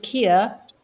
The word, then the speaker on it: Keer: So my name is Keer. You can spell it as K-E-E-R.